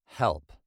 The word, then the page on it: help: British English